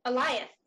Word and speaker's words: Eliath: Oh, Eliath. – Eliath, thank you. See, I can't even remember how it's said. Eliath. Like Goliath, but just with no G.